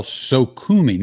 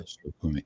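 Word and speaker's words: Sokhumi: Farewell, Sokoumi. Notice I'm pronouncing it the way you said is the Georgian way to pronounce it, right? Farewell, right. Sokoumi.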